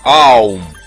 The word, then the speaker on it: Аум: Это была самая первая мантра, и в человеческом произношении она звучит как слог «Ом». А точнее, как «Аум». Или поток гласных звуков, переходящих один в другой. М в конце. конце – это не буква «М», а прекращение звучания гласных, когда человек смыкает губы после произнесения звука «У».